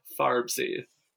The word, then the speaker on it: Farbsy: Yes, that is spelled F F is in Frank, A R B is in Bob, S Y farbsy now spell the at sign.